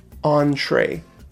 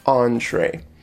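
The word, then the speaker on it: entrée: So after your appetizers, you're going to order your entree or your main dish. Now this word is pronounced entree. It looks like entry, but it's entree.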